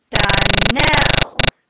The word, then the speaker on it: Donnell: Donnell. Accent on the last syllable.